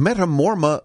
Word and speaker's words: Metamora: And Larry in Metamora, Michigan. Hope I'm saying that right. – Metamora. – Metamora. – Yes.